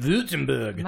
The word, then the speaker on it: Württemberg: But Carmella, in Württemberg, Germany, I think that's how you say it, Württemberg.